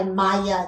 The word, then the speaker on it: Mayer: You're actually wrong. You're saying it right by Maya. It's actually M-A-Y-E-R.